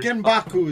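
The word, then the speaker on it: Genbaku (原爆): Genbaku is how you say it in Japanese.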